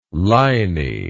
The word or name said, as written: liny